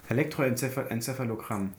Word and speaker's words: Elektroenzephalogramm: Also dieses EEG, kannst du auch aussprechen, Elektroenzephalit-Diagramm? – Enzephalogramm. Elektroenzephalogramm.